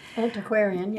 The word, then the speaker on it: Antiquarian: The other was in the 1905 Essex Antiquarium. Am I saying that right? – Antiquarian, yeah.